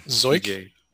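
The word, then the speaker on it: Zeuch: Anthony Kaye, TJ Zook, I mean, or Zuck, or how you, I don't know how to pronounce his name. – Zoik. – Zoik, there it is.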